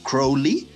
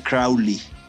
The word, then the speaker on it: Crowley: Entonces, pues evidentemente, pues Ozzy Osbourne lo tomó y pues hizo suya una, una canción acerca de él, que por cierto lo, pronuncia mal, en lugar de decir Crowley, dice Crowley.